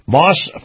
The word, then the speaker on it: Maus: Now, keep in mind the Truckers Highway and Byway Report is always brought to you by Moss Family Automotive. Moss is spelled M-A-U-S, Moss Family Automotive, where four words come into play.